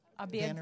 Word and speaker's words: обед: Обед. – Dinner. – Обед. – Обед. – Обед. – Обед. – Обед. – Обед. – Like abed. A bed, almost, or a bed.